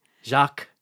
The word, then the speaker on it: Jacques: Jacques. – And then how do you spell that? – R-E-V-A-U-X.